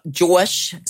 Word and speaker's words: JAWSH: Which I didn't know how to say his name until I was like, is it Josh 685? But it's Josh. Of course it is. – J-A-W-S-H. – He really won the heart of the nation that night.